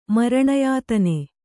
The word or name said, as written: ಮರಣಯಾತನೆ